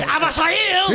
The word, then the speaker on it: Abreu: That's right, it's pronounced Avasail!